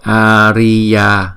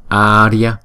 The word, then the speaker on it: Arya: Namo Arya, cái này không phải là đọc là Arya mà đọc là Arya. Ria, Ria, Ria, Arya.